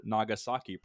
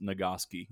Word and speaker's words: Nagasaki: I heard Nagasaki pronounced Nagaski.